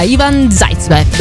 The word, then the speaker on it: Ivan Zaitsev: Quindi tanti tanti auguri a Romina e a Ivan Zaitsev.